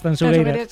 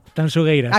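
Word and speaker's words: Tanxugueiras: A ver, repítemelo, como tengo que pronunciarlo bien. – Tanchugueiras. – Sí, esto me lo corrige Fermina, Agustí, mucho a Marta, por favor. Digo, mira que yo siempre pronuncio. A ver, otra vez, dímelo. – Tanchugueiras. – Acaba de deshacerse la manifestación a las puertas de la Gran Vía, de lo bien que lo has dicho.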